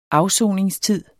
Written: AFSONINGSTID

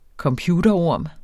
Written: computerorm

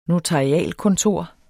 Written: notarialkontor

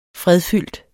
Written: fredfyldt

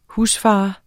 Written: husfar

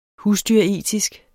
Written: husdyretisk

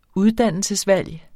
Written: uddannelsesvalg

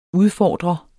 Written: udfordrer

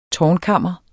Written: tårnkammer

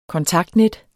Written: kontaktnet

